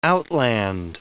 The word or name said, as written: outland